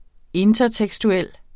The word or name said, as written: intertekstuel